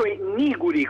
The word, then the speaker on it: Niguri: Bene, allora, intanto Niguri, con l'accento sulla I, in dialetto calabrese vuol dire neri o peggio negri.